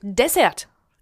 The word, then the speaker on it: Dessert: Zum Beispiel in der Gastronomie sagt man nicht Buffet, sondern Buffet. – Ja, oder? – Und Dessert. – Genau. – Da wird immer die erste Silbe betont.